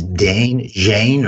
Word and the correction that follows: Zayn: Zane. That's spelled Z-A-Y-N.